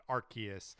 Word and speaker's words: Arceus: I don't know if it's a revelation, but just we've been saying Arceus on this show, but the narration at the end of this video definitely said Arceus with the hard C, like K sound, Arceus, and not Arceus.